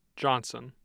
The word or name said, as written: johnson